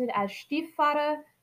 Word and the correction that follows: Stiefvater: I took German. I see German words and I go, ooh, how do I pronounce that? So my apologies, it's Maggie Stiefvater. Oh my gosh, I still say it slightly German. Oh.